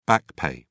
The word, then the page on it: back pay: British English